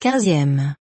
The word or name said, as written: 15eme